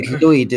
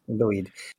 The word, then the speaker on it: Lwyd: It's the Mary Lwyd is how you'd probably say it. – Lwyd? – Lwyd. Yeah. W in Welsh is basically can be a consonant, but it's also a vowel, and it can have an oo sound when it's a vowel.